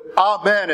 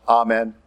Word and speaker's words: amen: And we are supposed to put amen at the end of it. Just a little footnote, that's the way it's pronounced. Amen. It's Hebrew. It's not amen.